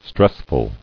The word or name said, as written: Stressful